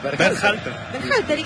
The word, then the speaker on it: Berhalter: Berhalter.